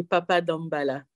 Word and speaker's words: Papa Dambala: Et c'est euh, Toto Bicente que tu as choisi de nous faire écouter avec ce titre Papa Wombala, si je le prononce bien. Mhm. c'est Toto Bicente et Papa Dambala. – Papa Dambala.